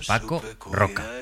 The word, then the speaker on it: Paco Roca: Interesante el viaje y la conversación que hemos tenido en el comando con Aitana y con Nacho, con Paco Roca.